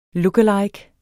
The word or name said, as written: lookalike